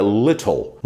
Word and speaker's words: little: Algumas regiões eles falam little, little, mas na maioria da Inglaterra, na maioria ali da, do Reino Unido, eles falam little, little, a little, um pouco. Já a pronúncia americana, little, little. O T tem o som do R.